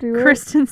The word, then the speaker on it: Kristen: I touched on it, where I was like, everyone thinks that Christian's, everyone thinks that Christian, I can't say her name. – Kristen Stewart.